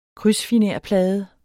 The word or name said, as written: krydsfinerplade